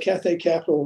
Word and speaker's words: Cathay Capital: Let's talk about your work with, am I saying it right, Cathay Capital? Is that the? – I got involved with Cathay Capital, and we'll get to Moon Surgical, because I for sure don't want to leave this conversation without expressing my enthusiasm for it.